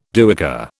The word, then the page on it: duyker: British English